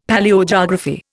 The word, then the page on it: Palaeogeography: Indian